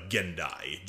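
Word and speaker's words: Gen'Dai: So, as we all know, Durge is a member of a race called the Gindai. Gendai. there's a lot of different pronunciations I've heard, but a lot of people generally call them the Gendai. G E N apostrophe D A I.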